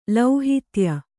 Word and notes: ಲೌಹಿತ್ಯ: lauhitya

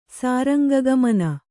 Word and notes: ಸಾರಂಗಗಮನ: Kannada

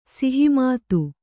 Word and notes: ಸಹಮತ: saha mata

